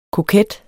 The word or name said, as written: koket